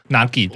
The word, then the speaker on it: 和: 对，所以那个时候其实看到她自我介绍，比如说她一开始的时候就是跟大家讲说她是叫井上和，但她和是念作 Nagi。